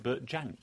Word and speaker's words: Jansch: But actually, going back to Bert Jansch, that's interesting because apparently he himself pronounced it Bert Jansch.